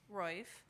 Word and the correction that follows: Roiphe: It's by Katie, Roiphe.